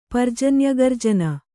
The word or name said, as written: ಪರ್ಜನ್ಯಗರ್ಜನ